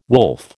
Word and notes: wolf: American English

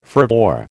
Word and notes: fervour: American English

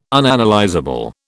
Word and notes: Unanalysable: British English

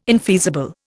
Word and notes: infeasible: Indian

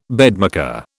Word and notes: bedmaker: British English

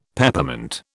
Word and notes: Peppermint: British English

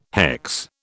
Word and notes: hex: British English

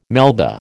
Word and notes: Melba: American English

